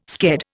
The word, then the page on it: sked: Indian